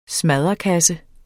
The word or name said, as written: smadderkasse